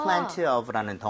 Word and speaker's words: plenty: 때에 따라서 여러분들 plenty 대신에 t를 약화시켜서 발음 안 하는 경우도 많아요. 그래서 plenty of. – Plenty of. – 그런 식으로 가도 괜찮습니다. 근데 발음도 중요하지만은 이 plenty of는 충분한, 많은인데 주의할 게 있죠.